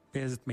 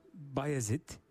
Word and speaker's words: Bayezid: Umarım elinizdeki kitap Beyazıt Meydanı hakkında güzel hayaller kuranların işine yarar. Günümüzde Beyazıt şeklinde telaffuz ettiğimiz ismin doğrusu Bayezid'dir.